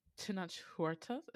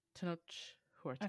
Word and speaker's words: Tenoch Huerta: Tenoch Huerta? Is that how you say his name? Tenoch Huerta. – I think so, yeah.